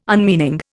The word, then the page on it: unmeaning: Indian